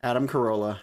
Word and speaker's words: Adam Carolla: And Acerola, I'm never, ever in my entire life going to be able to pronounce that name correctly. Acerola. – Adam Carolla.